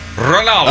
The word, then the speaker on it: Ronaldo: Is it Ranadol? – It's Ronaldo.